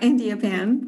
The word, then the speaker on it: Andiappan: I'm going to say Andiappan, no? – Uh, Andiappan, but close okay.